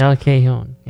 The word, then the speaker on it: El Cajon: El Cajon? Is that how you pronounce it? Because J is not pronounced right or right. – Something. – El Cajon. Yeah.